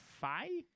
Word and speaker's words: Fei: And then Brittany Oldford is playing a character named Fei, I believe it's pronounced, F-E-I, which is going to be Sparrow number 3.